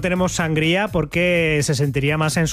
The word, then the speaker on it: sangría: Bueno, vosotros decís sangria, ¿no? Lo pronunciáis mal. – Sangría. – Decís sangría. – Sangría. – No, pero es que le ponéis ahí el acento en la A, en la primera A.